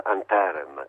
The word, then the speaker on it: Anterem: Noi preferiamo chiamarla anterem, ci abbiamo fatto slittare l'accento tonico. – Ah, giusto, sulla E, sì.